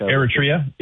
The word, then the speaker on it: Eritrea: You have countries like Eritrea, I think is how you pronounce it, Northern Africa. – Eritrea, yeah. – Oh, yeah, yeah. Very, very, thank you. I never knew how to pronounce that.